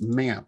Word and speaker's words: MAMP: I've not tried with MAMP. Is that what it's called? I always forget how it's pronounced. – Yeah, it's MAMP. I call it MAMP, MAMP.